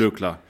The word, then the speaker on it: Glöckler: Harald Glöckler, so schwer ist es jetzt nicht. Glöckler.